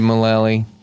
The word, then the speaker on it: Mullally: Move over, Megan Mullally. – Like Shillelagh? – Mm-hmm. – Is that how her last name is pronounced? – As far as I know.